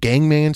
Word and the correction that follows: Gangnam: style or Gangnam style. I don't. It, it it's pronounced or not pronounced. It's spelled I think Gangman but it was pronounced Gangnam Style.